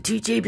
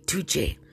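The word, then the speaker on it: Türkçe: I pronounce it Tuche because, well, how I was taught, they say that that's how you really pronounce the Turkish language, Turkish, in their language. They say Tuche, which I really thought was fascinating because it sounded like Italiano, right?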